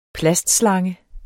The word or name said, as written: plastslange